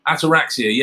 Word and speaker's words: Ataraxia: Ataraxia. – Ataraxia, yeah.